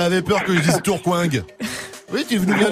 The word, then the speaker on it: Tourcoing: Il travaille dans les euh, transports et il nous vient de Tourcoing dans le Nord.